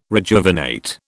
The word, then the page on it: rejuvenate: British English